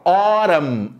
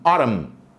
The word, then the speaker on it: autumn: Olha que pronúncia legal: autumn, autumn, autumn.